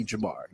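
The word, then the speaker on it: Jamarr: I wanted to make sure because you know some people they got like some like for instance, my first name is Jamar, and it has two R's, and the, the second R is silent.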